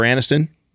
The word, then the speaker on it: Aniston: With Jennifer Aniston, and you said her name right.